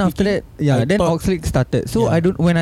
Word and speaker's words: Oxlade: Oxdale. – How do I say this? – Oxlade.